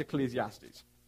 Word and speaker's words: Ecclesiastes: And you'll probably look at it and think, how do you pronounce that word? So that's Ecclesiastes.